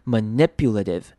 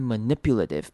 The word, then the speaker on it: manipulative: 也是一样念成轻音 manipulative，好，后面的 t i v e 的 t 也是一样会念成轻，会念成像是 d 的声音 manipulative，有没有发现重音在 n 这个声音上面 n，然后接一个短母音 i manipulative，好，所以这两个字要特别注意 deceptive。跟 manipulative，很多人可能会好奇，就是为什么最后那个 i 会念成不是 i，那这边会念成短母音 i 的声音，tiv，都是念 tiv 的声音，deceptive， manipulative。